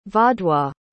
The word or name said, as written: vaudois